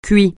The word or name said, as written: cuit